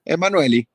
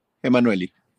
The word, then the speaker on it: Emanueli: Entonces yo creo que, no sé, es como ambiguo, pero bueno, siempre se le ha dicho por aquí Emanueli. y bueno, así quedó.